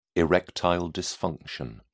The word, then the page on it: erectile dysfunction: British English